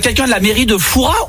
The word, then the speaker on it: Fouras: Eh bien écoutez, on dit Foura. On ne prononce pas le S.